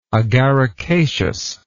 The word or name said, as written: agaricaceous